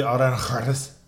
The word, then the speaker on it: Aranjuez: And the song Concierto de Aranjuez. I'm trying not to be funny, but that's how it's said.